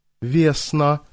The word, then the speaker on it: Весна: «Весна», — девушка сделала ударение на первом слоге своего имени, протянула Виктору руку.